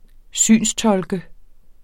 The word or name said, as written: synstolke